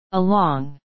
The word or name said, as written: along